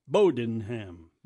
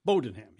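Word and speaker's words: Bowdoinham: The accents on the bow. Bowdinham. Bowdinham.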